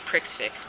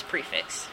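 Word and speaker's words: prix fixe: It's like P-R-I-X, which in French is pronounced pre instead of pricks. And then it's like F-I-X-E, which is just fix. – So you want to say pricks fix, but it's prefix.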